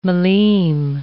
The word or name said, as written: millime